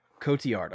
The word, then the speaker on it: Cotillard: Marion Cotillard.